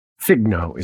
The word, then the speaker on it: FGNO: Figno is how I pronounce it.